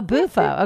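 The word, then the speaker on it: Bufo: What is Bofu or Bofu? How do you pronounce it, first of all? – Bofu. – Oh, Bofu. Okay.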